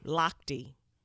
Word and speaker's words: Lochte: Lochte.